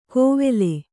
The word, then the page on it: ಕೋವೆಲೆ: kannada